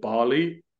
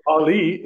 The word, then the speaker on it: Ballie: Um, I pronounce it Bali because it should be named Ball E. Um, and they dropped the ball on that one. but Ballie is a smart home ro- robot from Samsung.